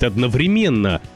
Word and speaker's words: одновременно: что варианты «одновременно» и «одновременно» абсолютно равнозначны. Просто журналистам и дикторам внушают, что возможен только вариант с ударением на третий слог, но вы их не слушаете.